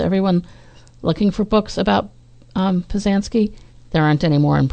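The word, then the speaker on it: pysanky: Quick aside, speaking of eggs, the North County Branch Library got a Pazansky egg. Is that how you pronounce that? – Yes.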